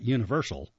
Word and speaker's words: UniverSoul: Just so we know, let me spell that for you. It's UNIVERSOUL. So it's not Universal. It's Universal Circus.